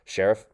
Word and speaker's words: Scherff: Scherff.